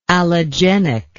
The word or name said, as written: allogenically